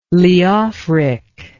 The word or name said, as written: Leofric